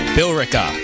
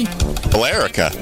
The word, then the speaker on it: Billerica: Bill Eric. It's Billerica? Bill? What? How do you pronounce that? Billerica. There's an E in there. – Billerica. – Billerica, maybe? – Billerica. – Oh, that makes sense. Just leave out the E.